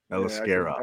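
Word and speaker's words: Aliskerov: I know his how to pronounce his name. At least I can help you on that one a little bit. – Which one? – Aliskarov.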